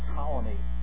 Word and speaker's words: Ptolemy: Problem was, there was a pharaoh at the time of Euclid, and his name was Ptolemy the First, spelled with a P. P-T-O. Okay, Ptolemy.